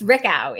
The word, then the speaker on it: Richau: It's Richau. It's not okay. Most people think it's French. It's more German.